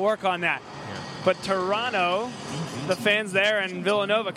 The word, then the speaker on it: Toronto: Toronto, Trying- don't don't pronounce the T. Don't pronounce my Canadian friends will be very upset with you.